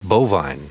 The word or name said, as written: bovine